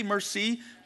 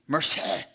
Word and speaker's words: merci: It's merci, but you have to get it out of the back of your throat for the C, so it's merci, right?